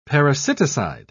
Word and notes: parasiticide: British English

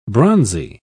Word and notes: bronzy: British English

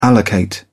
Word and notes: allocate: British English

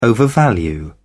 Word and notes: overvalue: British English

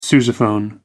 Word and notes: sousaphone: American English

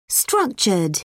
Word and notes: structured: British English

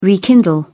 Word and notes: rekindle: British English

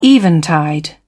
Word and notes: eventide: British English

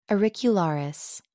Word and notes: auricularis: American English

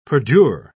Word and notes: perdure: British English

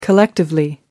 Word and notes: collectively: American English

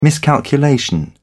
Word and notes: miscalculation: British English

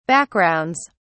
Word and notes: backgrounds: British English

